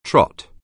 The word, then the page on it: trot: anglais